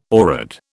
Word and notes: oread: British English

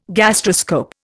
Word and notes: gastroscope: Indian

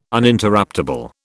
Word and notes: Uninterruptable: British English

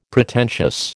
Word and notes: Pretentious: American English

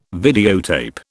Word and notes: videotape: British English

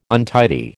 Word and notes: untidy: American English

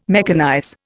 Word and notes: mechanize: Indian

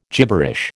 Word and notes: gibberish: American English

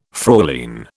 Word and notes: Fräulein: British English

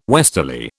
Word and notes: westerly: British English

